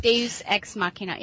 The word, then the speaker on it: Deus Ex Machina: Is that Latin, Deus Ex Machina? – Yeah. – How do I pronounce it? Yeah. – Deus Ex Machina, yeah.